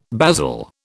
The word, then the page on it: basil: British English